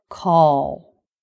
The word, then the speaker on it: call: The word call. C-A-L-L. So the L is a dark L at the end of a word.